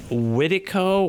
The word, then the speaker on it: Wendigo: Wendigo or Wendigo? The Wendigo, yeah. – How do you say it? – Yeah, Wendigo.